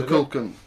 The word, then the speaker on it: Kukulkan: It was also known in certain religions as Kukulkan. – Yeah. – That's my pronunciation. – Kukulkan, yeah.